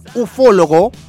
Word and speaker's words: ufólogo: Por una parte, un ufólogo, con acento en la O, en la primera O, en rigor, se dedica al estudio de objetos voladores no identificados, los ovnis.